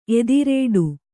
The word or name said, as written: ಎದುರೇಟು